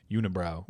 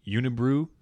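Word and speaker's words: Unibroue: Unibrow, which I hear people pronounce u- Unibrew, but it's gotta be unibrow.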